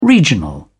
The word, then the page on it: regional: British English